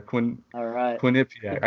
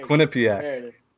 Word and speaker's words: Quinnipiac: Quinnipiac. – I can't pronounce it. – Come on, Quinnipiac. – Quinnipiac. Quinnipiac. Quinnipiac.